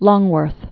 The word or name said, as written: Alice Roosevelt Longworth